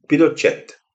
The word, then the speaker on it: Pinochet: So apparently the correct way to pronounce his name is, in fact, Pinochet. It just sounds a bit weird.